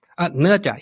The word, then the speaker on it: Nezha: Is it Nezha? – Nezha, yeah.